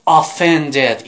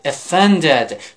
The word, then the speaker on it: offended: و لطفاً نگید offended، این تلفظ غلط. Offended تکرار کن. آفرین، با من بگو offended.